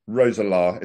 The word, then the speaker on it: Roeselare: And forgive my pronunciation, KSV Roeselare. Is that correct? – Correct.